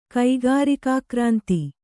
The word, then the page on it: ಕೈಗಾರಿಕಾಕ್ರಾಂತಿ: kaigārikā krānti